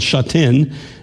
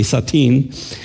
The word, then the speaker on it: Sha Tin: One of the churches in Hong Kong that is still there today, it's in a part of Hong Kong called Sha Tin. That's the way most of us pronounce it. If you're Chinese, you say Sha Tin.